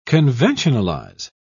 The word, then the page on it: conventionalize: British English